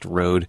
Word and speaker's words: Rohde: His last name, by the way, is spelled R-O-H-D-E, pronounced Rode.